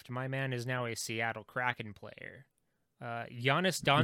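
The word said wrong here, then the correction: Joonas Donskoi: No, you have to say it how that football player said it. – Jonas Donskoy. – Jonas. He said Jonas Donskoy.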